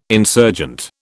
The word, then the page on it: insurgent: British English